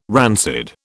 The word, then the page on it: rancid: British English